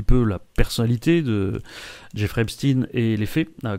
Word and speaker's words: Epstein: Je rappelle qu'on dit Epstein parce que c'est un Américain. On dit Einstein parce qu'il était allemand. Mais normalement, pour Epstein ou pour Weinstein, on dit comme ça parce que c'est comme ça qu'on prononce ça aux Amériques.